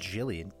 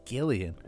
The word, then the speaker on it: Gillian: Episode 56, Sharp Objects by Gillian Flynn, I believe is how it's pronounced. Yeah, not Gillian, Gillian.